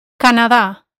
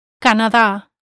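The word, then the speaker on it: Canadá: Notice that Canada has the stress on the last A. Canada, Canada.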